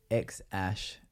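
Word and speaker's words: X Æ: X-A Ash. No, X-Ash. The A-E is pronounced Ash.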